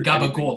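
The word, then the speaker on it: gabagool: I used to hate people who pronounced the Gabagol because it's actually, you know, Capicola is the name. And, you know, Capicola turned into Capicol, which turned into Gabagol from, you know, people in Jersey, no offense.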